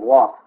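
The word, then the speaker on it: waw: Well, that middle letter is the letter Vav, and it has an alternate name, Wah, pronounced Wah.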